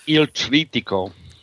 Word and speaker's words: Il Trittico: Il Trittico.